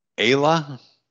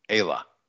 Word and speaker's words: Aella: How do you say her name? Ayla? A-E-L-L-A. Ayla. I guess that's right. And if it isn't, we're just going to say it is and go on.